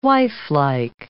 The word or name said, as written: wifelike